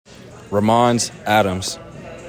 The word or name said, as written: RAMONZ ADAMS JR